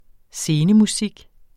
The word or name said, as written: scenemusik